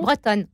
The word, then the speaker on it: Bretones: J'ai bien prononcé votre nom Bretonne. C'est bretonne, comme une vraie bretonne.